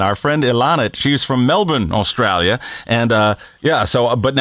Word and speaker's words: Melbourne: You have to say it right. They say Melbourne. – Well, that's what I was doing. I was consciously leaving the R very silent there, you know.